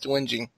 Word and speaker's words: whinging: Thank you for clarifying that it actually is pronounced whinging.